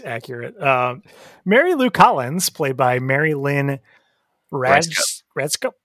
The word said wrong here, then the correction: Rajskub: It's spelled R-A-J-S-K-U-B. It's Rice Cup? – Cub. – All right. – Cub. With a B.